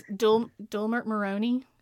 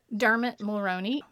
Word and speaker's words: Dermot Mulroney: Who was Dermot Mulroney? Is that how you say his name? Yeah. – Yeah, Dermot. – Dermot Mulroney.